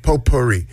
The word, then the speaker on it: potpourri: There's a silent T in there. – P O T. – P O T. – T. – P O T P O U R R I. – It's not potpourri. – It's silent T.